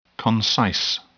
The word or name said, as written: concise